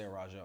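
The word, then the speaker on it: Araújo: I'm going to say Araujo. I think that's more Brazilian Portuguese-esque how they probably would pronounce it.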